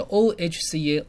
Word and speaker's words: OHCA: 我们常看到报纸上面讲的说，这个人到院时死亡，在英文写的是 Ohca， Oh 就念 O， Ca 是念 ca。